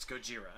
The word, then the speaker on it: Gojira: There's a guy who found it, who the girl is looking for, and he called it Godzilla or Gojira. So they proceed because he was shamed into leaving Japan for the United States. – Gojira. – So in English it's Godzilla, in Japanese it's Gojira.